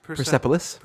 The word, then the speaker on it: Persepolis: That's I'm going to mispronounce this now. Persepolis? – Persepolis. – Persepolis. Sorry about that.